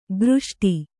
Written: ಘೃಷ್ಟಿ

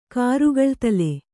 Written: ಕರ್ಗಳ್ತಲೆ

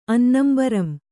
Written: ಅನ್ನಂಬರಂ

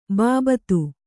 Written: ಬಾಬತ್

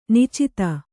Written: ನಿಚ್ಚೈದೆ

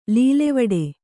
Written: ಲೀಲೆವಡೆ

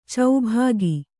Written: ಚೌಭಾಗಿ

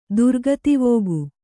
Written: ದುರ್ಗತಿವೋಗು

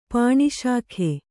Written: ಪಾಣಿಶಾಖೆ

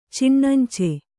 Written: ಚಿಣ್ಣಂಚೆ

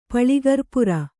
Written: ಪಳಿಗರ್ಪುರ

